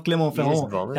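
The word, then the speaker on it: Clermont-Ferrand: I just ruined his nickname. The TGV of Clément-Ferrand. That's how you say it.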